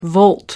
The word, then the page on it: volt: American English